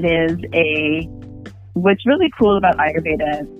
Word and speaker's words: Ayurveda: You practice something called Ayurveda. Did I pronounce that correctly? – Yeah. – All right. Yeah. All right. All right. – So, yeah, you did good. Ayurveda is that's the way I say it.